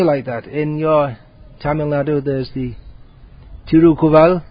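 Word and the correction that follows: Tirukkural: Is that how it's pronounced? Tirukovil. Tirukovil. Sorry, my Tamil pronunciation never got off the ground. Probably won't in this lifetime. So it's... No, Tirukovai is something else. Tirukural. Tirukural, that's it.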